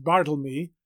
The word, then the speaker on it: Bartholomew: Of the non-Shakespearean poems in The Passionate Pilgrim, several are written by four known poets: Richard Barnfield, Bartholomew, probably pronounced Bartlemy, Griffin, Christopher Marlowe, and Sir Walter Raleigh.